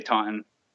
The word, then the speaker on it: Taunton: Yeah, it's a little city called Taunton, and there's different pronunciations for it, but the locals say Taunton, but I guess technically it would be Taunton.